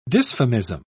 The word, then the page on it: dysphemism: British English